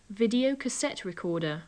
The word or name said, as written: video cassette recorder